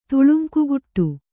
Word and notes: ತುಳುಂಕುಗುಟ್ಟು: tuḷuŋkuguṭṭu

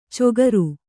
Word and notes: ಚೊಗರು: cogaru

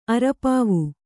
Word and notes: ಅರೆಪಾವು: arepāvu

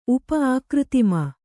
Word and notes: ಊಪು: Kannada

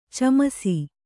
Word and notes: ಚುಮ್ಮಿಸು: cummisu

